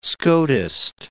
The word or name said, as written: Scotist